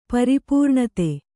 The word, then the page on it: ಪರಿಪೂರ್ಣತೆ: pari pūṇate